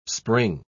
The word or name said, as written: spring